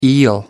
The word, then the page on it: eel: American English